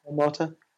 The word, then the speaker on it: Automata: and then remember Nier Automata, or is that how you pronounce it? – Yeah, Automata.